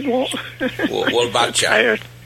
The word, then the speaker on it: Wolbachia: You know, I thought that the Wolbachia, am I saying that right? – Wolbachia. – Wolbachia, there we go,